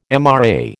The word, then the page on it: MRA: American English